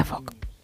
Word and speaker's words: folk: Su regla clave es que la letra L es muda. – Anda. – Con lo cual se pronuncia folk.